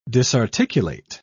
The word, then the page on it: disarticulate: British English